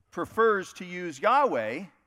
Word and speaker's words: Yahweh: Some people translate or pronounce it Jehovah, but Yahweh is the right pronunciation.